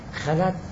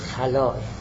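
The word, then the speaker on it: خلاء: البته خلا که ما تعبیر میکنیم، اینو شاید حالا بعدن هم عرض میکنم، خلا تعبیر غلطیه، تعبیر درست خلاه. منتها چون ما فارس ها خلا رو به معنی دیگر هم تطبیق میکنیم، لذا سعی کردیم خلا تعبیر کنیم، تلفظ کنیم، ولی تلفظ صحیحش خلاه.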